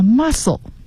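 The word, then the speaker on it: mussel: the catch， catch 是名词，它是捕获量啊，尤其适合于这个鱼捕鱼业。好，然后再来一个词，如果我念这个读音好多人就觉得这词儿不值得一讲啊，叫 muscle，它和肌肉发音一样 muscle， but it is spelled as muscle 啊，它拼写和肌肉是不一样的。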